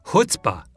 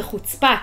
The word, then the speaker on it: chutzpah: You hit the second syllable. – Chutzpah. – Chutzpah. There's a lot of air coming out of the mouth when you say that. – Why? So but in America, the accent's on the first syllable. – Yeah. Chutzpah. – Chutzpah. – Right. – Chutzpah and chutzpah. And they are worlds apart.